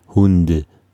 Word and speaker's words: Hund: Auch bei Hund obwohl, da steht eigentlich das weiche D, Hunde. Hund.